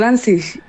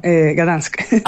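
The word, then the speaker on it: Gdańsk: Y lo digo Danzig. Y es como, no, Gdansk.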